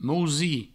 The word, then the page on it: mousir: mousi